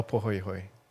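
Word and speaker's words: pāhoehoe: A pohoihoi. Yeah. Okay. Did I say that correctly? – Yeah.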